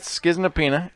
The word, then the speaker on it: Schizonepeta: How do we spell it? – S-C-H-I-Z-O-N-E-P-E-T-A. – E-T-A. – Schizonapina. – It's schizonapina. It is.